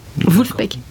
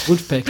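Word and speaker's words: Vulfpeck: Un groupe assez moderne que j'aime beaucoup, c'est Vulspec. – Wolfpack. – Ouais, ah oui, prononce-le pour moi. Prononce-le pour moi, merci. – Wolfpack. – Ouais, voilà.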